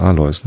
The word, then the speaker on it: Alois: Wie spricht man das richtig? Alois, ne? Ja.